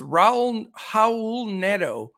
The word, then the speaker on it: Raul Neto: The second pick for Crash, it's Raul Neto. That's how you say it, and it always gets me.